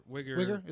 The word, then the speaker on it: Uyghur: You know, they're probably Uyghur Muslims. Am I saying that right? I don't know. But like the concentration camp. – I saw some video online. They call them Uyghur. – Uyghur? Is it Uyghur?